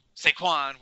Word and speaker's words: Saquon: You know, Saquon, which rhymes with Stanquan,